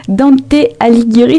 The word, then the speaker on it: Dante Alighieri: J'ai vu que vous étiez en collaboration avec les partenaires locaux comme les cinéastes et l'association Dante Alighieri. Je ne sais pas si ça se dit comme ça, je suis désolée. – C'est très bien dit, bravo pour la prononciation.